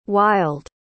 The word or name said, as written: wilde